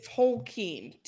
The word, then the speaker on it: Tolkien: It's Tolkien. TOWL.KEEN. Tolkien.